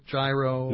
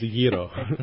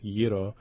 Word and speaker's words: gyro: Now, you said gyro because I just want to make sure because, you know, sometimes you hear, like, gyro. – No, it's gyro. Okay. It's a soft G, soft G-Y combination, if you will. So it is pronounced gyro, and it comes with, it's a lamb and beef combination with spices.